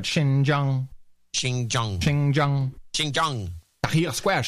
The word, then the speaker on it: Xinjiang: We also got a correction for Xinjiang, that place in China. – Yeah. – Xinjiang. Xinjiang. Xinjiang.